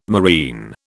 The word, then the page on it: moreen: British English